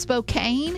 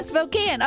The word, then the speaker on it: Spokane: We passed a sign call that was for, I thought it was pronounced Spokane, and everybody started laughing. Yeah, Spokane. I was like, oh, Spokane, I've heard of that. They're like, well, we haven't. Spokane.